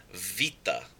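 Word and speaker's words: vita (βήτα): Beta or beta has become vita. Vita, the B has become a V sound, a V sound.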